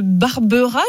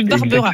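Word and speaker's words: Barberaz: Vous nous appelez de Savoie, de Barbera, je crois qu'on prononce pas le Z dans la région. De Barbera.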